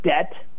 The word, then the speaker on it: debt: And so what they did is try to reflect the origin of the word in our English spelling, even though we never pronounced that B in the first place. So they took this word debt, added a B, and they added it because the word debt comes from a Latin word, debitum, that means, well, debt.